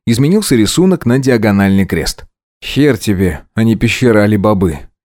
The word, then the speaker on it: хер: Я тут же вспомнил, что диагональный крест является 27-й буквой в древнерусской азбуке, или буковице, и произносится эта буква именно «хер».